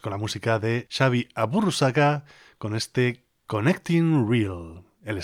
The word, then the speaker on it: Aburruzaga: y en este caso se trata de Xavi Aburruzaga, si lo intentamos pronunciar un poco a la vasca, Aburruzaga, si lo pronunciamos más a la castellana.